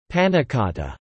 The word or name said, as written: pannacotta